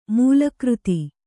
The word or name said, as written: ಮೂಲಕೃತಿ